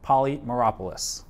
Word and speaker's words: Polymeropoulos: It's a great honor to have Marc Polymeropoulos.